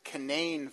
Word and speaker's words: Canaan: We have the Canaan Valley about 2 hours north of us, except I didn't know the proper way to pronounce the Canaan Valley is to pronounce it the Canaan Valley.